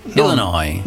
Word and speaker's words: Illinois: Ecco, ripetilo bene il primo, che l'Illinois non si legge la S finale, tutti sbagliano, invece si dice Illinois. – Illinois.